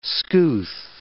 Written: scouth

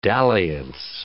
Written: dalliance

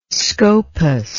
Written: scopas

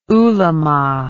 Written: ulema

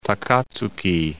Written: Takatsuki

